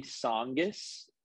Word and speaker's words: Tsongas: Yeah, so uh, we're gonna say Tsongas. It's spelt very weirdly. Uh, if, if you just look in the in the title of this episode, I've been told the T is silent.